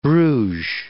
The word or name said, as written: Bruges